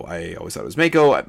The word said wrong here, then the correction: Mako: Mako, I guess, makes more sense because it, Japanese pronunciation, it would be Mako.